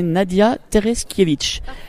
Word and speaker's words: Nadia Tereskiewicz: Alors que j'écorche pas ton nom, c'est Nadia Tereskiewicz. C'est ça? – Parfait.